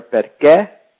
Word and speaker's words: Perché: Notice the accent on the E there. Perché?